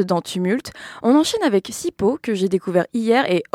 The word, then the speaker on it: Sipho: Oui, si comme moi, vous aimez ces timbres de voix qui hurlent la sensibilité et la puissance, eh bien vous allez adorer Sipo. D'ailleurs, hier, en googlant son nom, parce que ça s'écrit S-I-P-H-O, le premier résultat sur lequel je suis tombée est apparemment un appareil d'étirement qui soulage les mal de dos.